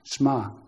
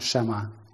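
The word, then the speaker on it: shma (שמע): In fact, shma is better, actually. It's not shema. Although it's spelt shema, it's shma.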